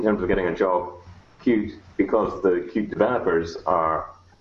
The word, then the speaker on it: Qt: it's pronounced Qt, it's one word.